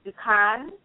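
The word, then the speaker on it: dukan (दुकान): Okay, so the last word is going to be store, like a grocery store, and it is pronounced dukan is how it's pronounced, and it's spelled D-U-K-A-N-A. D as in dog, U-K-A-N-A. It's pronounced dukan.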